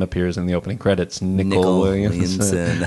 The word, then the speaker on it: Nicol: I often hear his name mispronounced, but I learned very early on how to pronounce his name. Nicol Williamson. – Nicol.